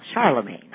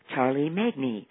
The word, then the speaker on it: Charlemagne: And for example, Charlemagne. I just said, this word is Charlemagne. What word? Charlemagne. Of course, if they tried to sound it out, it would be Charlie Magne, not close.